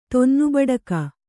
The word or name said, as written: ತೊನ್ನುಬಡಕಿ